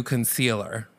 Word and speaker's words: Koncealer: And they switched the pronunciation to Koncealer with a K.